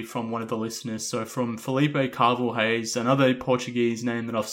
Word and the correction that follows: Carvalhais: First of all, I think it's Carvalhais. – Carvalhais, okay. – Yes, I think it's with a J sound instead of an H sound.